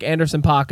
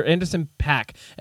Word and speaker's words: Anderson .Paak: He's done some work with like Anderson .Paak and Anderson .Paak and some really good. I've had to correct that recently. I've been saying his name wrong ever since I listened to him.